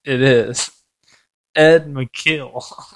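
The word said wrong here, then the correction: Macauley: Is that McCauley? – Yeah, my bad. Easy Ed McCauley? – Yep.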